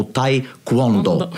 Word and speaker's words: Taekwondo: Atleta do Taekwondo, é assim que se pronuncia, Joana. Taekwondo. Eu tenho sempre a dúvida se é Taekwondo, Taekwondo ou Taekwondo. – Taekwondo está bom. – Taekwondo está bom.